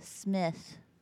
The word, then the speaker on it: Shmit: Smith. – Is it spelt weird? – Yes. – Please spell it. – S-H-M-I-T.